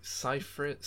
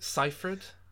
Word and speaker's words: Seyfried: Um, it's Seyfried. Seyfried.